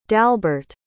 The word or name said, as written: dalbert